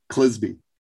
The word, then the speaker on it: Clisby: It's written by a man named Kent Clisby, rhymes with Frisbee.